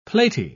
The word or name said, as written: platy